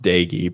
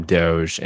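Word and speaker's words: Doege: Apparently his last name's pronounced Dagey, but I prefer to call him Doge.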